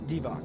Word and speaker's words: Divac: We still don't know how to pronounce his name, do we? – It's Divac. Divac.